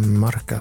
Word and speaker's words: Marca: Eh, tisdag igår eh, blev det klart, i alla fall enligt eh, sporttidningen i Madrid, Martha. Martha. Är det så man uttalar det? – Marca, tror jag.